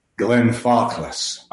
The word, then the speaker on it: Glenfarclas: Can you say that again? – Glenfarclas. – Okay. I've been saying it wrong.